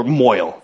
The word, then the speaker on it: Mohel: So eventually I just said, okay, listen, I want to stay on topic, so why don't we have the Mohel Minute, or Moil, however you want to say it. It's spelled Mohel. Mohel. So we had the Moil Minute for some time.